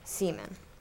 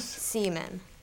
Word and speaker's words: Seaman: Seaman. – Nemus? – Seaman. – Neiman. – Seaman. – Spell it. – S-E-A-M-A-N.